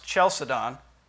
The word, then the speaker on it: Chalcedon: I believe it should be properly pronounced Chalcedon. Chalcedon. – Part CH like chasm.